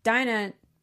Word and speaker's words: Dinah: But, you know, for Dinah, did I say it right? – Yes.